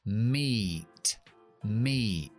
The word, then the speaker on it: meat: O por ejemplo, la palabra carne, que es M-E-A-T, pues solo pronuncio la E, que es I en el abecedario. Meat, meat. Eso se llaman sílabas de equipos de vocales.